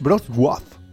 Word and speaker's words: Wrocław: Mira, desde Brovla, porque, bueno, la pronunciación es Wrocław, con una abierta.